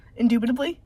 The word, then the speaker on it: indubitably: I have a hard time with undubitably. – Indubitably? – Indubitably.